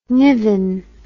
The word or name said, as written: niven